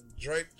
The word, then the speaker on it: Drayton: D-R-A-Y. Daytona is spelled D-A-Y-T-O-N. – I didn't say Daytona. – Drayton. It's Drayton. My bad. – Dayton is spelled D-A-Y-T-O-N.